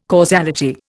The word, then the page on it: causality: Indian